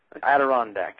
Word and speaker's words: Adirondack: Now, the value proposition that was submitted is get a quality brand name, Adirondack chair. I'm not sure I'm getting it right. I'm not saying I'm selling it, saying the name correctly. – Adirondack? – Adirondack, that's it.